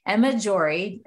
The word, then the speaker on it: Jory: So I want you all to welcome Emma Jory.